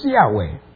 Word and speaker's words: Yahweh: It's a secret tetragrammaton that's not really pronounced, but the way we pronounce it is Yahweh,